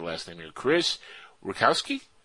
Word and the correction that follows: Rutkowski: Rukowski. I have yeah. no idea. – Rutkowski. I'm going to go with that.